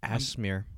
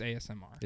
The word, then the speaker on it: ASMR: Is that how you pronounce it? – Oh, I don't know. – No, it's ASMR. – It's ASMR.